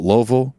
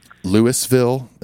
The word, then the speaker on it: Louisville: There's the Louisville set list, or if you're from Louisville, Louisville, however you want to pronounce it, Louisville.